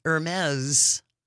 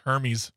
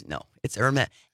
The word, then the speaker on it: Hermès: consumers, three women from California, are suing French luxury house Hermès. – Because how you pronounce it? – Hermès. – All right. – It's very snooty. – I thought it was Hermes. – No, it's Hermès. Hermès.